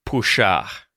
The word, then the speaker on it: puxar: The word for pull, to pull, in Portuguese is puxar, which sounds like to push, but it's spelled P-U-X-A-R, puxar, and that means to pull. Empurrar, E-M-P-U-R-R-A-R, means to push, right?